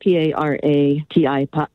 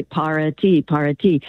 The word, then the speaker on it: para ti: So, for example, when I was studying Spanish, there's an expression for you, and it's spelled P-A-R-A-T-I. And so most English speakers will say para-ti. My Spanish teacher said, wait a minute, go in the kitchen, put on some water, and make a pot of tea. I go, para-ti. When I started saying that, then I knew the pronunciation correctly in Spanish. Bharati.